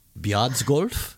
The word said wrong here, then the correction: Fjaðrárgljúfur: Uh, Alex. Fjaðrárgljúfur. – Yards golf? No. No, a massive fail.